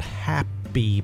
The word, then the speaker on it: Hapbee: It looks like a big, well, it's a black ring, and it fits over my head, and it's called Happy, but it's spelled H-A-P-B-E-E.